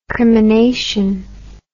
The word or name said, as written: crimination